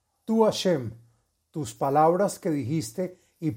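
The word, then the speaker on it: השם: Se deberá meditar el santo nombre de Hei Shin Mem, que se pronuncia Hashem.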